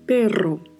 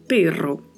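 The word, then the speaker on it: pero: E temos que ter outro cuidado aqui com a pronúncia, porque o R tem um vibrato, então precisamos pronunciar perro, sendo que a letra E pronuncia-se com som de E e o O também mais fechado. Então, perro.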